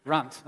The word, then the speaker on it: Runt: Because my last name's Hunt. Runt. Okay, let's see what rhymes with Hunt, and that's the one we landed on.